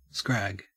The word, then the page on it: scrag: Australian English